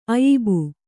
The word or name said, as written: ಅಯಿಬು